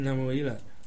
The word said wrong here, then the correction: Lemuel: Can somebody read the name pronounced properly? Lemuel. Lemuel, I think it's out. Chapter 31 verse 1. Lemuel. Somebody read it? Who was reading it? Huh? King Lemuel. Lemuel, okay.